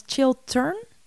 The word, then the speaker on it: Chiltern: Children Firehouse. – Yeah. – As in children. – No, it's Chiltern. Chiltern.